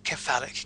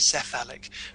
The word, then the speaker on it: cephalic: Uh, it's, it's spelt with a C, but it's pronounced K medical world always throws me these curveballs. now I am giving a workshop, and a medic goes, "That's actually uh, a, cephalic, not cephalic," and, and, I have to learn learn the right term. Um, I'm using the right term, but pronounce it the right way.